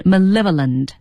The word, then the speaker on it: malevolent: M-A-L-E, male, 哈，男性哈 V-O-L-E-N-T, 啊，读音是 malevolent, 重音在 L-E 上面 malevolent,、 yeah.